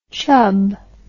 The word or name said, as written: chubb